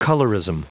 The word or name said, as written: colorism